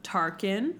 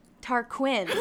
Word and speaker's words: Tarquin: How do you fucking say his name? – Tarquin. – Do you want to say Tarquin? – Let's review the spelling.